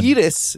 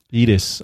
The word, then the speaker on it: Iris: I watched Gamera 3, Revenge of Iris. – Geek Boner. – What did you think of this one? – Edis, if you want to say it right. – Edis.